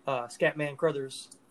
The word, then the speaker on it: Scatman Crothers: Like, he was known for doing multiple shots of the same scene, which wore her out, and then also Scatman Crothers.